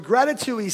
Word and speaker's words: gratitui: It rhymes with ratatouille. So a gratitui